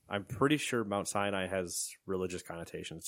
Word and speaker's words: Sinai: Center at Mount Sinai. That's how I would pronounce that. – Okay. – It's not Sinai, which is the other way I would say it.